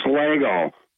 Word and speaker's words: Schlagel: it's actually pronounced Slagle. It's spelled S-C-H-L-A-G-E-L.